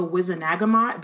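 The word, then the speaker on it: Wizengamot: Or in the movies they call it the Wizenagamot, but then that doesn't make sense because he adds an extra syllable. – The Wizen... – Because in well, because the G and the N would have to be switched for it to be Nagmot. Because it's a hard G.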